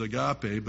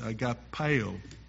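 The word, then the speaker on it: agapao: That word for love in the Greek, spelled A-G-A-P-A-O, I've heard it pronounced as agape, but it's agapeo in the Greek.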